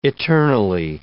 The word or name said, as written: eternally